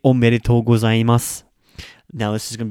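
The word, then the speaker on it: omedetou (おめでとう): So if you're saying the original omedetou, omedetou, no, this is a long O at the end, that's the traditional congratulations.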